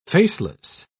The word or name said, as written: faceless